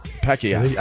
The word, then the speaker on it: Pacquiao: Right, say it the way it's supposed to be said. – All right. – Pacquiao. – That's how they say it. Pacquiao.